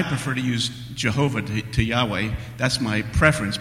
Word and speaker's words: Yahweh: Now, later on, so you might pronounce that Yahweh,